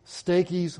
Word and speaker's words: Stachys: At the end he says, greet Stachys, my beloved. Now, it's misspelled in the outline here. That's my fault. It should be S-T-A-C-H-Y-S, Stachys. That's how you pronounce it.